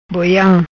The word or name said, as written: Boiam